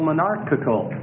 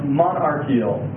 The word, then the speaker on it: monarchical: It's called monarchical. Monarchical. – Sometimes it's pronounced monarchial. Both ways. Monarchical. – And that's spelled M-O-N-A-R-C-H-A-L.